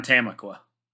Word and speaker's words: Tamaqua: I was going to say, he's right in downtown Tamaqua. – Yep. You pronounced it perfectly.